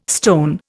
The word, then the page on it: stone: Indian